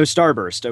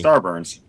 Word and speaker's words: Starburns: Dino Starburst. Okay, yummy. – Starburns.